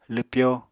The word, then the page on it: lwpyo: apatani